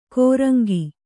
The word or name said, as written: ಕೊರಂಗಿ